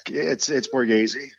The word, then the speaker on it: Borghese: Yeah, it's Borghese. – Okay. – Borghese. Unless you're in Italy, but we're in the U.S., so we'll stick with it.